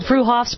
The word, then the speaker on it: Freehoffs: Spelled Freehoffs, pronounced Freehoffs at 33rd and Arapaho in Boulder.